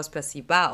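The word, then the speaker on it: Spasiba (Спасибо): Ja, ich muss sagen, da habe ich auch ziemlich lange gebraucht, weil sie hat mir Spasibo aufgeschrieben, wird aber Spasiba ausgesprochen.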